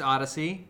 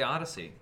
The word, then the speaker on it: ODY-C: It's just Odyssey. It's spelled ODY-C. Yeah. I think you just say Odyssey.